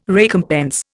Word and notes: recompense: Indian

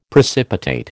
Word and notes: precipitate: American English

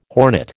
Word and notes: hornet: American English

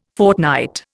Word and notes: fortnight: Indian

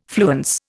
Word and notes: fluence: Indian

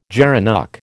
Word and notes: gerenuk: American English

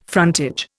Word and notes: frontage: Indian

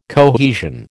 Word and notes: cohesion: American English

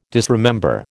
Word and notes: disremember: American English

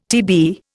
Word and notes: TB: Indian

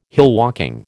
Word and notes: hillwalking: American English

